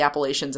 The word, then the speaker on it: Appalachia: He's an incredible artist and is and represents like the Appalachians in this like beautiful way. – Okay. – I'm probably saying Appalachians. – Appalachia. – I never know how to say it right. – Appalachia. Appalachia.